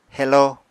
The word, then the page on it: heló: apatani